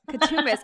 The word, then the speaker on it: Coutsoumbos: Coutsoumbos.